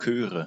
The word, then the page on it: Chöre: [ˈkøːʁə]